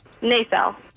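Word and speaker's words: Naselle: Am I saying it correctly? Is there emphasis on the first syllable? Is it nacelle or nacelle? – Nacelle. – So are you emphasizing the first syllable or the second syllable? – I think the first.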